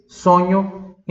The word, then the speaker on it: sogno: Falo sonho em português, sonho em italiano, anche così, é a stessa cosa. Sonho, repita, sonho. Sonho se escreve S-O-G-N-O. Tá vendo como o G-N substitui o N-H em italiano?